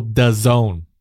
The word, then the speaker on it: DAZN: Not days and people, DAZN. DAZN, phonetically.